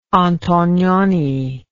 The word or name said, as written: antonioni